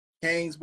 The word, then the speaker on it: Canes: Kane's Barber, C-A-N-E-S, Kane's.